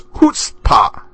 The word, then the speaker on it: chutzpah: Hearing you say Poirot perfectly the other day reminded me to send you the correct pronunciation of chutzpah. It is chutzpah. The CH is pronounced like the H, and I know I've probably said chutzpah a few times.